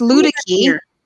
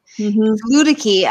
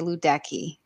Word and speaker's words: Luedecke: It's Ludicky. Ludicky. I know most people probably say Ludecky. And that's what they automatically. It drives my husband crazy.